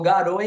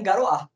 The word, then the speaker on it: garoa: Aí ele transformou garoa em garoá.